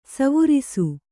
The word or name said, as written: ಸ್ವರಸ